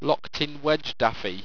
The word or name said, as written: loktinwedgdafie